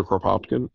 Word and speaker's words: Kropotkin: So I got Anarchist Communism by I can never pronounce his name, Kropotkin. – Yeah, Peter Kropotkin.